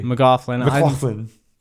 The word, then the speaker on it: McLaughlin: Did you pronounce that correctly? – McLaughlin. – Yeah.